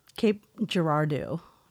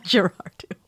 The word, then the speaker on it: Girardeau: Cape Girardeau, Missouri. – Okay. – Almost like Depardieu. – But. – Girardeau.